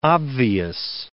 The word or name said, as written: obvious